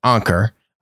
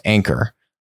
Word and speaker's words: Anker: Um, I pronounce it Anker. I guess it's actually supposed to be pronounced Anker, but whatever. Anker, Anker, Tomato, Tomato.